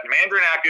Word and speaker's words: Mandernach: but Mandernach is how a lot of my, my older relatives would pronounce it.